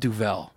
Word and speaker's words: Duvel: I called it a Duval. – Hmm. – But it's called Duvel, according to her.